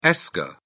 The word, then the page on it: esker: British English